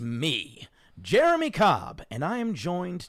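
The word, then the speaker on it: J'er'em'ih: In reference to Jeremy, spelt J apostrophe E R apostrophe E M apostrophe I H. pronounced like Jeremy from Dimension 20's side quest, Escape from the Blood Keep.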